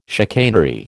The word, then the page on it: chicanery: American English